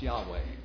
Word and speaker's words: Yahweh: But the vowels would tend to be pronounced Yahweh.